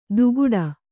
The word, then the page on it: ದುಗುಡ: duguḍa